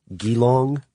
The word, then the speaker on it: Geelong: said this letter in 1932 received in the Ford Australia plant in, I'm going to mispronounce this, Geelong, Victoria.